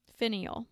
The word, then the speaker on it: finial: And there is also a separate $100,000 reward for the return of the Napoleon Bronze Eagle finale. Finial. That's how you pronounce it.